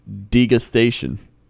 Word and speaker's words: degustation: I just Merriam-Webster it, and it's degustation. That's the actual pronunciation.